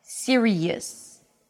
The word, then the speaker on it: serious: Serious, series. Three syllables, serious, two syllables. Series, all right?